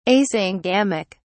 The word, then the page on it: asyngamic: British English